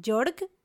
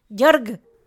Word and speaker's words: Georg: En su certificado de matrimonio se registró como George, no, no George, Jorg, o sea, como se escribe Georg. O sea, supongo que es. El vato era alemán, entonces supongo que se, ha de decir como Jorg.